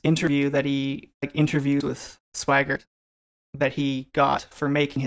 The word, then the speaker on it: Swigert: I always call him Swigert for some reason. – No, it's pronounced Swigert. It's spelled Swigert.